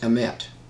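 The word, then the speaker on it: emet (אמת): There's a Hebrew word pronounced emet, A-M-E-T. Mm-hmm. It starts with the Aleph and it ends with the Tav.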